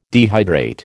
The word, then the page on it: dehydrate: American English